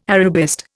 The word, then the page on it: Arabist: Indian